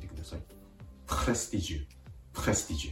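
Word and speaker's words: prestige: 続けて発音すると、プレスティジュ、プレスティジュです。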